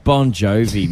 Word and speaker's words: Bon Jovi: as Bon Jovi. – Hot 100. We are giving away the Hot 100 tradie truck. – It's pronounced Bon Jovi, mate.